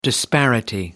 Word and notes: disparity: American English

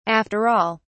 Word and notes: after all: British English

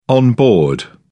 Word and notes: on board: British English

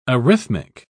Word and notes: arrhythmic: British English

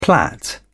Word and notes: plait: British English

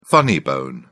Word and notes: funny bone: British English

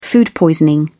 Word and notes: food poisoning: British English

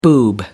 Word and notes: boob: American English

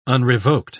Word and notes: unrevoked: British English

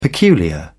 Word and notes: peculiar: British English

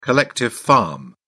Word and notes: collective farm: British English

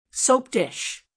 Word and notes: soap dish: American English

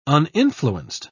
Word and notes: uninfluenced: British English

